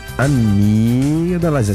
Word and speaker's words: amígdalas: São considerados cânceres de cabeça e pescoço aqueles que atingem a boca, as cavidades nasais, gengivais, bochechas, amígdalas. Essa aqui é difícil. – Amígdalas. – Que são as amígdalas. – Não produziu o G, que é mudo, né? – É.